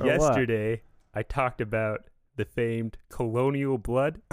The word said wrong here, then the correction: Colonel: It's actually pronounced Colonel Blood. And Colonel is spelled with an L.